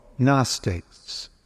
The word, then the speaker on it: Gnostics: Or do you want the ones that were written much later by the so-called Gnostics? That word is spelled G, capital G, N-O-S-C-I-C-S. The G is silent.